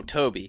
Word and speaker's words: Tobe: So how do you say it, Toby or Tobe Hooper? Oh, Toby. Toby. Okay, because I've heard both, and for I sure.